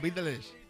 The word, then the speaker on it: Beatles: Esto es de los Beatles... y escuchemos los Beatles. – De los Beates. Los escarabajos. – ¿Qué has dicho? Los Beatles. – Ah, los Beatles.